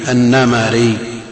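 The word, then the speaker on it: النَّمَري: الميم، أنا أريد ضبط الميم بالفتح، وهي في الأصل لا النمري، النمري بفتحتين، وهي في الأصل قبل النسبة مكسورة، نمر، نعم، مثل ملك، مثل سلمة، سلمي، فالنسبة إلى نمر نمري، سلمي، سلمة، سلمي، وملك ملكي، لأنه يثقل النطق بكسرات متوالية، نعم، يستثقلون هذا فيفتحون.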